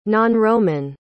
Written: nonroman

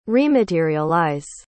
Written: rematerialize

